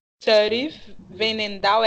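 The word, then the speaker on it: Sari van Veenendaal: Aí eu já fiquei, tipo, eu fiquei puta e feliz ao mesmo tempo, que foi a, a vencedora, foi a goleira holandesa, a Sharif Venendal, é assim que fala, né, gente? Pronuncia, né, Matheus? – É, é exatamente.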